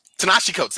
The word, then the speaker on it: Ta-Nehisi Coates: Most recently, he's been doing work with the Black Panther run with Ta-Nehisi Coates. I always butcher that name. Darn it. Anyway, him. Ta-Nehisi Coates. There you go. – Boom. Once you got it, you were on point.